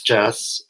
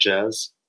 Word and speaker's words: Jess: It's Jess. The audiobook narrator did pronounce it more like Jez. – Yeah, with a Z, Yeah, which is, it's fine.